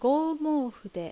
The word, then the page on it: Goumoufu: Japanese